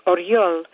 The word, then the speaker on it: Oryol: It's also the Russian word for eagle, the male eagle, pronounced Oryol, and not a girl's name at all in Russian, but luckily nobody at school knew that.